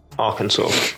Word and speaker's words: Arkansas: It is in Arkansas, USA. I think that's how you pronounce it. – Arkansas.